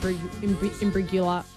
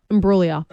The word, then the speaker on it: Imbruglia: Imbruglia.